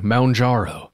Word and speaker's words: Mounjaro: It says Mound, like as in mountain, Mounjaro.